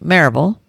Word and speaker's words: Maryville: I lived in, and still do, in Maryville, Tennessee, which is pronounced Maryville by the locals.